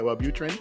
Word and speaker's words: Wellbutrin: You know, and if Wellbutrin, is that how you say it, Wellbutrin? – Yeah, that's one of the drugs.